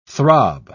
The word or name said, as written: throb